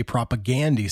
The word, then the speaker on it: propaganda: And I've always said propagandy because in my mind, it was like propaganda mixed with Gandhi. But then I've heard you and other Canadians say propagandy, so I, I was I was worried I would say it wrong.